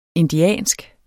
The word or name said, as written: indiansk